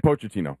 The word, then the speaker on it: Pochettino: Pochettino.